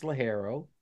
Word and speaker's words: ligero: Some people pronounce it ligero, but it's ligero, or that's Spanish for light.